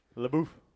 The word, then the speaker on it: LaBeouf: There's an F. – LaBeouf. – Yeah, but I think it's French or something like that, right? So I'm just going to go LeBou. I don't know. – French has an F too, and it's pronounced in the word French.